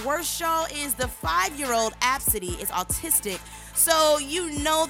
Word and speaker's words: Abcde: spelled A, B, C, D, E. But apparently, it's pronounced Absidy.